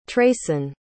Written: treyson

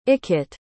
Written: ickett

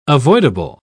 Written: avoidable

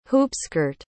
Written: hoopskirt

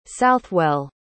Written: southwell

